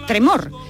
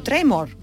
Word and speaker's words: tremor: Cuidado porque hay quienes quieren ponerse guay y eh, no lo pronuncian eh, tremor, sino tremor, como si fueran en inglés.